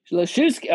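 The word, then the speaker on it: Lischewski: Christopher Lishwecki. How do you say that name? Leshewski. Oh, man, even better. Leshewski, yeah, sounds like something you would name a pet tuna.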